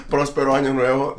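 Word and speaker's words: Próspero Año Nuevo: Prospero Año Nuevo. – How do you say it, bro? – Prospero Año Nuevo.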